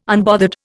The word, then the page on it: unbothered: Indian